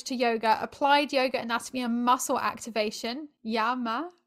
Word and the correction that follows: Ayama: Is that how you say it? – Ayama, see? Yes.